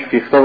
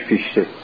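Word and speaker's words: Fichte: آقای کانت یک شاگردی داشت به نام فیخته یا فیشته، در اصل اختلاف تلفظاتی است که در زبان‌های اروپایی است. این آقای فیخته، گویا تلفظ آلمانیش فیخته باشه، فرانسویش فیشته،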